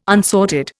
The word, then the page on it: unsorted: Indian